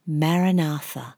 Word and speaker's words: Maranatha: I will guide you through a short body relaxation, and then we will bring the word Maranatha into our stillness. When we say this word, we break it up into four syllables. Ma-ra-na-tha.